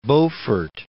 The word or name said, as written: Beaufort